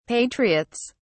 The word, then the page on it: patriots: British English